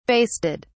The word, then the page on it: basted: British English